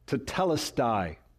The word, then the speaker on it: tetelestai: In the original language, it is but one word, and it is pronounced tetelestai.